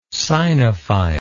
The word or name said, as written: sinophile